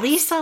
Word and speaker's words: Elisa: Elisa Lamb.